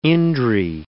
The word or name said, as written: indri